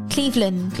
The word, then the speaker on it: Cleveland: Yeah, in Cleveland, not Cleveland. – Cleveland. – as I pronounced before.